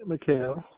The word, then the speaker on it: McHale: Yes, my name is Eugene McHale.